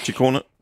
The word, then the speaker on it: Ciccone: Under Giro d'Italia var din holdkammerat Ciccone, er det rigtigt udtalt? – Ja, Ciccone.